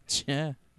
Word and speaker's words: JEAH: J-E-A-H. Jia? – Jia. – Jia? – Like chia.